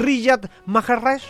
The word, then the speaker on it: Riyad Mahrez: Jugador a seguir en este partido, Riyad Mahrez, ex Manchester City. No sé si lo dije bien, ¿no? Riyad Mahrez. Ya, ahí está.